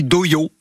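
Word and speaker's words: dohyō: Alors oui, on dit doyo, doyo, on prononce doyo, on dirait un peu un gars qui dit dojo, mais qui a rendez-vous chez l'orthophoniste, mais c'est bien doyo.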